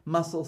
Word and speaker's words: muscle: The letter C is silent in the SC word combination, and the C is useless in front of a K or a Q. Muscle, scissors, ascent, miscellaneous.